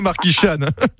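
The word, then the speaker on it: Marquixanes: Donc, voilà, vous l'écrivez sur le GPS, Marquichane, mais on prononce bien Marquichane. Je préfère vous le dire.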